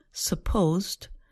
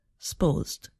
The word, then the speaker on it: supposed: Here, a two-syllable word, supposed, becomes more like one syllable, supposed.